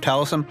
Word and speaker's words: Taliesin: It's pronounced, it looks like Taliesin, like the Book of Taliesin, but it's pronounced Taliesin.